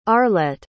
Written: arlette

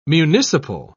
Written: municipal

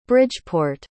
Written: bridgeport

